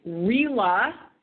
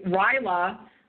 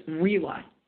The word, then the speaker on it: RILA: So it's RILA. And for anybody who's heard Brand Elverston say RILA for 30 years, that is a mispronunciation. It's RILA.